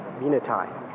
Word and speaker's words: Venetie: We'll hear argument now, number 96-1577, Alaska versus the native village of, is it Veneti? – Venetai. Venetai. – Venetai. How do they get that out of V-E-N-E-T-I-E? May I ask counsel for respondent, is the correct pronunciation of the native village of Venetai? – Venetai. – Venetai. Thank you.